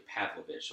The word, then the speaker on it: Pavlovich: It's actually Pavlovich. So we were close. Pavlovich.